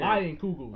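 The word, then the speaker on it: Leinenkugel: It is called Leiden Kugels. Barry Shandy. – Linken Kugels. – Linken Kugels. – Look. It has the pronunciation Lion and Kugels right there. – Lion Kugels.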